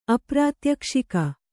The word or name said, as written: ಅಪ್ರಾತ್ಯಕ್ಷಿಕ